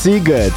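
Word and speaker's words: Sziget: Bo to jest bardzo ważna rzecz, bo pisze się Siget, a wymawia się Siget.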